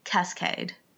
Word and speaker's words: Kaskade: It is K-A-S-K-A-D-E. Mm-hmm. Cascade.